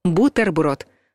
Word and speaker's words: бутерброд: Возможно, вы удивитесь, но звук «т» в этом слове твердый. Бутерброд.